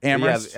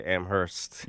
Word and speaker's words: Amherst: Only the H is silent, I believe. Amherst.